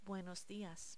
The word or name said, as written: buenos dias